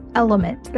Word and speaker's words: LMNT: This podcast is brought to you by Element, that's spelled L-M-N-T, which is a company that I had the chance to invest in and have loved since day one.